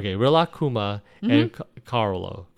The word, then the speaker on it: Kaoru: But yeah, it's Rilakkuma and, now I can say her name. Koro? – I think it's Kaoru. – Okay. – Kaoru.